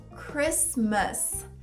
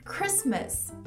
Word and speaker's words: Christmas: Castelo, castle. Castle, Natal, Christmas, Christmas. And Merry Christmas. Então esses são alguns exemplos onde o T é mudo.